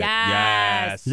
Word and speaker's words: yaas: Y-A-A-S, say yes. – It. Yes.